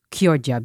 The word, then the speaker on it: Chioggia: Maar het is dus Chioggia beat. Net zoals bruschetta en Chianti. Ja. Omdat de CH in het Italiaans als een K wordt uitgesproken.